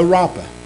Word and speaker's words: Rapha: So Rapha. Again, it's written R-A-P-H-A. It's pronounced R-A-P-A.